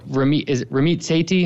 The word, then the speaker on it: Ramit Sethi: Yeah, so we I really like the you know, Ramit Sethi, am I pronouncing his name right? Yeah.